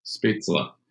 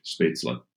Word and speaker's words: Spätzle: butcher and traditional German, how do you say that? – Spitzla. Okay. So that is Schwäbisch. That's a Schwäbisch dish, Spätzle.